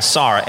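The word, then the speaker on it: Sar (שר): Let me spell it for you. Sar, S-A-R, Sar.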